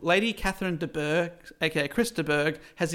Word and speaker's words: Bourgh: Is that how I say that? B-O-U-R-G-H. De Bourgh. – Wait, spell it again. I'm dyslexic. – B-O-U-R-G-H. – Well, that's Bourgh.